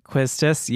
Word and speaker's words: Quistis: Quistis, yes. – Is that how you say it? – That's how I say it. – I think that's correct.